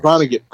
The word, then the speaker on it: Vonnegut: Vonnegut.